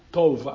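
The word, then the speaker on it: tov (טוב): Porque resulta que hay personas que dicen que eso se trata, ese bueno, que es la palabra tov, así se pronuncia en el hebreo, es T-O-B, tov.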